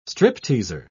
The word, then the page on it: stripteaser: British English